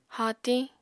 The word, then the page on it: haatiñ: Apatani